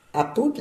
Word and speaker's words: apud: Et apud, l'accent tonique sur le A, ah, toujours sur l'avant-dernière syllabe.